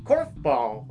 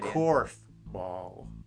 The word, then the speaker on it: korfball: Korfball. – Tell us about Let me say it again. Let me say it clearly. – Rupidia. – Korfball. – Can you spell that for us? – K-O-R-F-B-A-L-L.